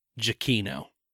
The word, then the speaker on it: Giacchino: No, that's because it's the pronunciation says my last name is pronounced Giacchino.